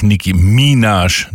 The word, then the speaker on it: Nicki Minaj: Justin Bieber featuring, und ich hab's tatsächlich wieder falsch gesagt, sie heißt natürlich Nicki Minaj, neben Minaj.